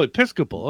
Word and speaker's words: Episcopal: We're talking about an 1898 Methodist Epistolic. How did I pronounce that? – Apostolic. – Episcopal. – Oh, Episcopal. Okay.